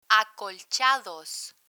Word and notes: acolchados: Spanish

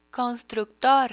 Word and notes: constructor: Spanish

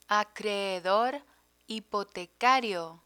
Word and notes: acreedor hipotecario: Spanish

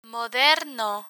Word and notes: moderno: Spanish

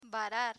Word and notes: varar: spanish

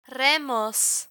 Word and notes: remos: spanish

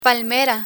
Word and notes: palmera: Spanish

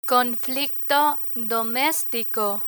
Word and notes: conflicto domestico: Spanish

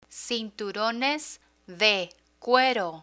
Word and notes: cinturones de cuero: spanish

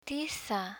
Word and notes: tiza: spanish

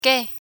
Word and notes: que: Spanish

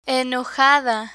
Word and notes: enojada: Spanish

